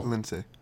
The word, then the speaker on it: Lince: Lince. That's how Michael Cole pronounces it.